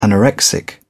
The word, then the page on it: anorexic: British English